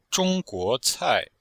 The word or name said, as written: zhong1guo2cai4